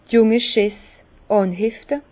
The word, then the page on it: d' junge Schess ànhefte: Bas Rhin; Herrlisheim